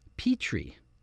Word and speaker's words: Petrie: Reiner himself is Rob, whose surname is pronounced Petrie.